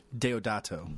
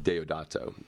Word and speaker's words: Deodato: Deodato. Deodato.